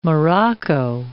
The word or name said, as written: Morocco